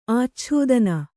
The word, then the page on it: ಆಚ್ಛಾದನ: ācchādana